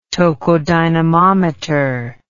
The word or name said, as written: tokodynamometer